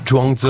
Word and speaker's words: Zhuangzi: Oui, Zhuangzi.